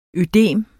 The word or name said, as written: ødem